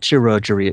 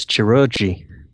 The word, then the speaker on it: chirurgy: No, it's not chirurgery, it's chirurgy.